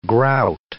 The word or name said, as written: grout